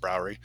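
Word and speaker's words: brouwerij: It's spelled with a J at the end. Browridge. Uh-huh. It's like Browridge. It's pronounced Browery.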